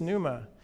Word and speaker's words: pneuma: In the Greek, it's pneuma.